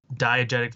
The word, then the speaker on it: diegetic: Like, you get kind of this musical, that kind of the term, what was the term you used before, like diegetic or whatever, or am I saying that right? – Yeah, it's a term that just references sound in movies. So, like, diegetic versus non-diegetic sound.